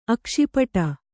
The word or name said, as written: ಅಕ್ಷಿಪಟ